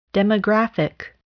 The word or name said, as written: demographic